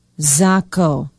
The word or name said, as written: zachow